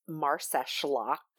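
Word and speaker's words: Marsaxlokk: So it has a lot of consonants in it, but it's pronounced Marseshlock. And it's got like an X in it. Their X is kind of an SH sound.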